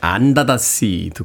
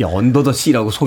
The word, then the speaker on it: Under the Sea: 애니메이션 인어공주에 수록됐던 사멜 이라이트의 안다다씨 듣고 왔습니다. 이 언더더씨라고 소개하면 안 되겠네요. 어, 이게 사실 안다다씨. 예, 원어 발음을 존중해야 되잖아요. 네. 안다다씨, 안다다씨라고 발음을 합니다.